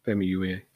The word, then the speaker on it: Famuyiwa: but it looks like Rick Famuyiwa. It's Famuyiwa. I want to get the name right because, yeah, he deserves to have his name pronounced properly. Rick Famuyiwa.